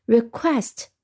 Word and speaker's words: request: request，我们先来看一下它的读音和拼写。嗯，读音我们要注意是 qu 这个组合的一个读音。那么 Q U 这个组合呢，它发的一个辅音呢是 k 和 w，那就是 W 的这个呃辅音声音 w 和 k，k 和 w 拼在一起 q，一般都是发成 K W 的发音 k，那么和后面的 est E S T 发成 est，quest 就发成 quest。